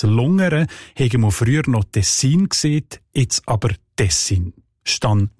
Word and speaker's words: Tessin: Und in diesem Dialekt sieht man Tessin oder Tessin, also auch auf der zweiten Silbe betont. Und im Italienischen ist es ja auch Ticino und nicht Ticino. Jetzt hat aber die deutsche Sprache die Tendenz, die Wörter auf der ersten Silbe zu betonen.